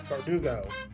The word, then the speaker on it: Bardugo: And our book this time around is Six of Crows by Leigh Bergudo. Did I say it right? Bardugo. I typed it wrong. That's why I said it wrong.